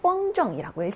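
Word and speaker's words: 펑정이라고 (风筝): 연은 중국어로 펑정이라고 읽습니다. 일성 경성이고요.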